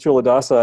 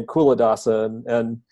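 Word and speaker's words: Culadasa: When I started with Chuladasa, I was pronouncing his name Kuladasa, and some people, like, they hear Chuladasa, and they still say Kuladasa, because, you know, it's just...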